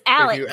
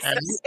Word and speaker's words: Alex: No, but it's Alex. – If you ask Mr. Murdaugh, it's Alec. – Right. Alec.